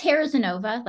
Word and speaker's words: Harazinova: Yeah, it's Harazinova, like hair.